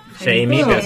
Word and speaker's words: Jaime: Jaime. – Jamie, se pronuncia así.